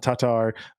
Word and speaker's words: Tatar: That two A's in Tatar are pronounced differently, apparently. – Oh, and so I'm trying it that. Tatar.